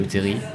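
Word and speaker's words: terril: J'ai notamment visité les friches, je suis, monté sur le terri, le terri, qui se prononce à la fin, comme Thierry.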